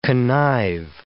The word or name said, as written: connive